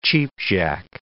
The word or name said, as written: cheapjack